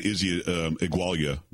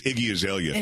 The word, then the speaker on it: Iggy Azalea: Because, Beyonce and Nicki Minaj and I always say her name wrong. I think I said Izzy Igualia the other day, but I think it's Iggy Azalea. – It is.